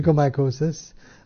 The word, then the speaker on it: onychomycosis: onychomycosis, however is the correct term of pronunciation? – Yeah, onychomycosis.